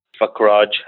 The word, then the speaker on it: Fakraaj: Fakraj Herbals. – Thank you for pronouncing that correctly for me.